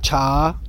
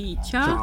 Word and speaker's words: 茶: Cha. – Cha.